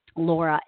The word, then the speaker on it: Laura: My name is spelled Laura, L-A-U-R-A, Moliter is M-O-L-I-T-E-R.